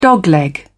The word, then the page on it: dogleg: British English